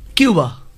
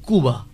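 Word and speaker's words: Cuba: যেমন কিউবা, আমরা বলি থাকি কিউবা, কিন্তু আসল উচ্চারণ কুবা, কিন্তু উচ্চারণটা না অনেক বেশি লজিক্যাল স্প্যানিশে।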